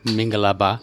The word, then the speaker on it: Mingalaba: Mingalaba? – Yeah. – Is that right? – Yeah. – Did I say it right? – Yeah. Yeah, you're right.